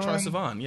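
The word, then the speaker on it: Troye Sivan: Oh, Troy Sivan, yeah.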